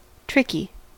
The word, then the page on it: tricky: American English